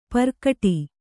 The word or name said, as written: ಪೆರ್ಗಟ್ಟ